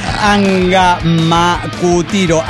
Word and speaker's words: Angamacutiro: Desde Agamoquiticra llega Ricardo Arrete. – ¡Ja! ¿Cómo estás, Ricardo? – Esto es imposible de pronunciar. Angamacutiro.